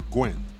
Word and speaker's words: Nguyen: Well, I was going to say if the Vietnamese Gwen, N-G-U-Y-E-N, the N is silent. So you just say Gwen.